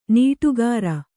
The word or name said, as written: ನೀಟುಗಾರ